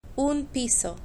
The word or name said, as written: un piso